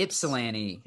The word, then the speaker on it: Ypsilanti: in Ypsilanti. – Mm-hmm. – Is that how you say it? Ypsilanti. – Yeah. It's that almost like the Y is not even there.